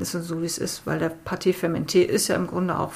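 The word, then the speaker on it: pâte fermentée: Im Zweifel ist das dann eben ein Vorteig oder beziehungsweise ein Pâté fermenté.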